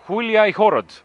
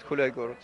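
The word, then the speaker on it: Hulyaihorod: So that means uh, mm, let's uh, uh, correctly pronounce the band's name. Huliai Horod. Yeah, Gulai uh, Gorod, Huliai Gorod.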